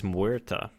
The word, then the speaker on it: Muerta: But the new hero is Muerta. She is Spanish, if you couldn't tell from my pronunciation.